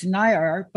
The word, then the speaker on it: Nair: You heard me pronounce it near, but it's not that. I'm going to pronounce it correctly. Uh, it's Nair, but it's N A I R.